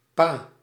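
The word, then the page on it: папа: /ˈpapə/; papa; Russian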